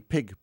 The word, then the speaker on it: pygg: They're named after pig, which is spelled P-Y-G-G.